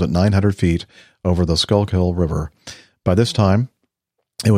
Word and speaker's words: Schuylkill: No, he said the Schuylkill. No, Schuylkill. Say it one more time. – Schuylkill.